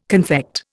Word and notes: confect: Indian

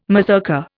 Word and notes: mazurka: Indian

